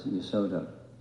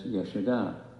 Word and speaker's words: Yashoda: That's why I try to be very careful with, like, we're used to saying Yashoda as Yasoda, but it's Yashoda because the female name has the long A at the end, commonly, like Prita or Kunti or Subhadra, commonly.